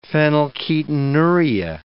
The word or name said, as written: phenylketonuria